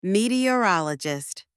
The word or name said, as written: meteorologist